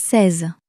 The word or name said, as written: seize